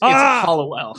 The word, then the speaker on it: Hallowell: It's you're so close. It's Hollowell.